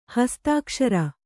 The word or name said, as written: ಹಸ್ತಾಕ್ಷರ